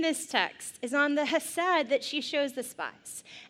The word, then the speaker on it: chesed: it's a guttural H, so it's chesed. Chesed.